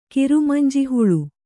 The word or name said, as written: ಕಿರುಮಂಜಿಹುಳು